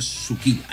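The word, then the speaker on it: Sucilá: Se escribe Susila, pero se pronuncia Suquila. Ahí está, Suquila. – Ah, Suquila. – Exactamente. Como Suquilo, pero es Suquila.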